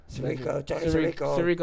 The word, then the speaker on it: Sirico: Tony Sicario. – Sirico. – I always say his name wrong.